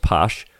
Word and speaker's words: pox: It's spelled P-O-X. It's pronounced posh.